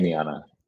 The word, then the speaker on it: Damiana: How do you say it? – Damiana.